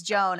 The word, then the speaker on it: John: But you have to still spell it J-O-H-N, but then in parentheses say it's pronounced. – Pronounced Joan. – And everywhere he goes, people are like, John Sloan? And he's like, it's Joan, actually.